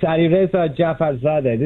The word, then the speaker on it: Alireza Jafarzadeh: Well, I know, I think I said it right. Alireza, did I say it right? – Perfect. It's Alireza Jafarzadeh. This is the best pronunciation I've gotten the whole day.